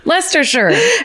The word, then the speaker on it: Leicestershire: I spelled it out Leicester, sure. – Leicestershire. – And Narborough I read out like gnar, like G-N-A-R, like gnarly.